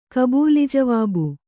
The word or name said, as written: ಕಬೂಲಿಜವಾಬು